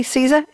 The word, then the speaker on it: SZA: And R&B singer Siza, S-Z-A. I have no idea how to. Matt, Mustafa, did I say that correctly? Siza, S-Z-A? – Yeah.